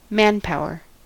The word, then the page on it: manpower: American English